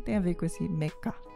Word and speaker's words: Mecha: Mas ele se pronuncia mecha, né? Vem de mechanical, e mecanicaru, né? A pronúncia japonesa, né, de mechanical, que vem de mecânico mesmo, né? Algo que é, é mecânico. Aí fala mecanicaru, e aí ficou meca, mecha.